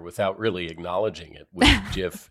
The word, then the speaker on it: GIF: You know, as I understand it, the person who invented it said it's supposed to be pronounced that way. But many people still persist with GIF.